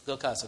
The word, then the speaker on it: Gurkhas: Gurkhas, how do you pronounce that? Well, Gurkhas, okay.